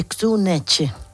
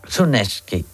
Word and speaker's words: Kuczynski: Bueno, la pronunciación es la síndrome de Kuczynski. – Kuczynski.